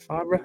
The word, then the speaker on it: Favre: Is that, I can't even remember how you say his name. Is that Favre, isn't it? Brett Favre? Favre? Am I saying it right? – Favre? – Yeah, is that it? – Yeah.